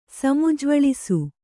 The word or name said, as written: ಸಮುಜ್ವಳಿಸು